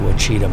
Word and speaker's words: Ouachita: I was going to go to the Ouachita Mountains. And it's spelled O-U-A-C-H-I-T-A, Ouachita Mountains, because I think everybody goes to the Ozarks.